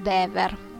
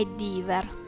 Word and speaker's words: Dever: Però nel 2015, anno in cui ho avuto la fortuna di trovarmelo davanti ad uno stand del Lucca Comics and Games, ho scoperto che la pronuncia corretta è Dever, e pertanto è così che mi sentirete chiamarlo.